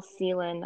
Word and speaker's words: Celan: So Bono had been reading this poet, Paul Celan, I think is how you pronounce his last name, C-E-L-A-N.